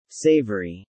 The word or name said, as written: Savoury